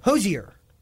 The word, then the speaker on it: Hozier: No, Hozier is actually correct.